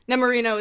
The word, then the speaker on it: Nemorino: is it Nemorino? – Yeah. – Am I saying that right? Yeah. Nemorino is the biggest simp in all of opera.